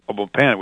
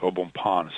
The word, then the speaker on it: Au Bon Pain: Or you can pronounce Au Bon Pain sometime here in Boston, which that's what the company originated.